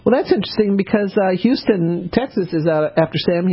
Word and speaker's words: Houston: I have to get off at Houston, and I'll, you know, I've lived here long enough. I say no, it's spelled Houston, it's pronounced Houston.